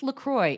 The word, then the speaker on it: LaCroix: It's LaCroix. Enjoy LaCroix. I emailed the company to check. – Okay. – They're like, it rhymes with enjoy.